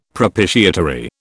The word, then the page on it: propitiatory: British English